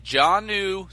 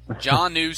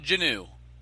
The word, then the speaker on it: Jonnu: It is John New Smith. Say it just like John and the word new. Jonnu Smith. That's how you say it. It's not Janu.